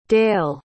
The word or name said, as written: dael